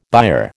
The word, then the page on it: byre: American English